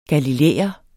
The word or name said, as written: galilæer